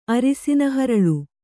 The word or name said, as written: ಅರಿಸಿನಹರಳು